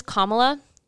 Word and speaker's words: Kamala: But her name is Kamala. Pronounce it as such.